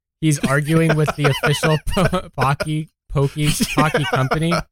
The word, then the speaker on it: Pocky: It's actually pronounced like Rocky, except with a P. And then he's like arguing with them. He's like, yeah, but I heard on TikTok that it was pronounced Pokey.